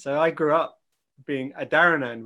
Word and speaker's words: Adharanand: It's Adharanand is how I, so the H is definitely there, whereas I, Adharanand, I do it like that. Adharanand. – Adharanand, right. – It's a rhyme with another land, Adharanand.